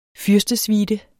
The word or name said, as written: fyrstesuite